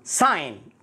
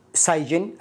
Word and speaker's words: sign: sign وليست سايجن الجي لا ينطق ساين